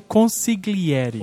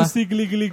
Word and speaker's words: consigliere: Assim, no mesmo nível do sotocapo, tem o consigliere. Que é uma palavra tão simples, mas que ninguém consegue falar. – Consigliere. – Mas, na verdade, eu acho que nem tem esse G, né? Na pronúncia.